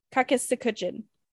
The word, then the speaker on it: Kakisikuchin: and his name was Kakisikuchin. And I can't pronounce it very well because it's not my language, but that was his name, Kakisikuchin. – Nailed it. – Thank you. – Nailed it for sure.